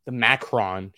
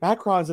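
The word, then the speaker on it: macaron: I told my wife that this was Super Bowl Sunday coming up, and she is like the Macron. She had to correct me on my pronunciation of this. The Macron King. She's like, oh, I can make macarons in the shape of footballs and stuff.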